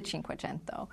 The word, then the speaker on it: Cinquecento: It is the Cinquecento. It's just we pronounce it 500 in the U.S.